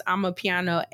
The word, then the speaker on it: amapiano: Pronounced I'm a piano, A-M-A-P-I-A-N-O.